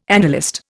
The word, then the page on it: annalist: Indian